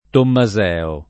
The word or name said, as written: Tommaseo